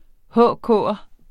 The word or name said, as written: HK'er